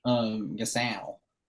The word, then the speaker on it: Gasol: The better Gasol. How did I say that? – Yeah, I don't know why you said it like that, Gasol.